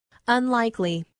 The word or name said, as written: unlikely